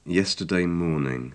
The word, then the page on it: yesterday morning: english